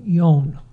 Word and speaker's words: yon: So in Japanese, what they'll do is they'll use the Japanese pronunciation as they go ichi ni san yon.